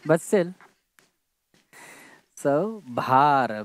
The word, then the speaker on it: भार: It's not bar, it's bhar.